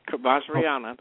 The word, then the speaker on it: Vajrayana: You know, Vajrayana is a country pronunciation. That's how I pronounce it. I am the country. – That's the correct way to say it. That's right. Vajrayana. That's correct. Mm-hmm.